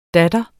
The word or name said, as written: datter